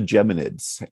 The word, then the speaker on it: Geminids: Did I say that right? – Yes, yes. It's called the Geminids.